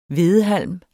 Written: hvedehalm

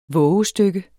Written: VOVESTYKKERNES

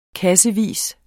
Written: kassevis

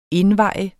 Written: indveje

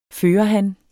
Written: førerhan

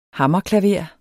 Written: hammerklaver

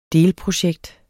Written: delprojekt

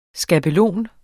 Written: skabelon

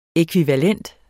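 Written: ÆKVIVALENTE

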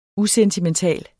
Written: usentimental

